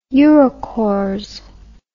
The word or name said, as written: eurocorps